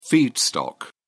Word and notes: feedstock: British English

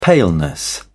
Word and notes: paleness: British English

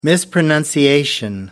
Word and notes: mispronunciation: British English

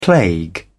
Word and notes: plague: British English

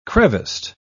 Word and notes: creviced: British English

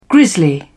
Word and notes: grizzly bear: British English

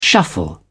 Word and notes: shuffle: British English

